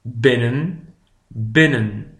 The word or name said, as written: binnen